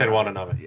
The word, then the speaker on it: Watanabe: Ken Watanabe.